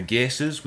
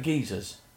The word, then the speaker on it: Geysir: And then geysers. – It's pronounced geyser, I think. G-E-Y-S-I-R.